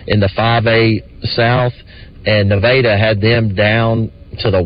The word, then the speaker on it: Nevada: Or I believe we say Nevada, but Nevada, I believe, is how they say it.